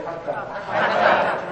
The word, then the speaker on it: Hatha: Same with Hatha Yoga. Okay? Say Hatha. Turn your tongue back. Hatha. That's the correct pronunciation.